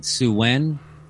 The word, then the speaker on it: Samhain: And it's spelled like Samhain, but I think they pronounced it Suwen.